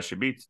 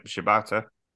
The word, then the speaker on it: Shibata: Uh, Yuta's called out Sh- uh, Shibita. I can't say his name. Shibata.